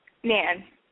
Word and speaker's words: Nan: Okay, and your name is Man? – Nan. – Wait, spell it? – N-A-N.